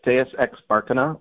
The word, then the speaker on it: Deus ex Barkina: Deus in Barkina. Am I saying that correctly? – Yeah, it's Deus ex Barkina, like Deus ex Deus, Machina.